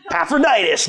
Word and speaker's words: Epaphroditus: Epaphroditus. – Good. And Timothy, that's an easy one, right? Timothy and Epaphroditus, they were faithful servants.